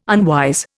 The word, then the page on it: unwise: Indian